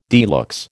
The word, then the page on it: de luxe: American English